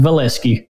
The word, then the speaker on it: Veleski: That's correct. It is pronounced Valesky.